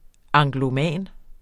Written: angloman

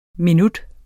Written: minut

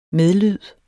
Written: medlyd

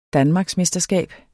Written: danmarksmesterskab

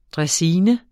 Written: draisine

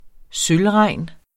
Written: sølvregn

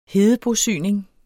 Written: hedebosyning

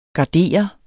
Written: garder

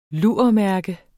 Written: lurmærke

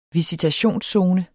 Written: visitationszone

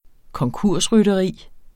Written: konkursrytteri

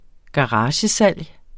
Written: garagesalg

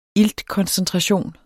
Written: iltkoncentration

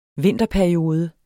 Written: vinterperiode

